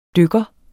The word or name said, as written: dykker